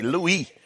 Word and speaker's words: Louis: It's spelled Louis, but you're supposed to do it the European way. Louis.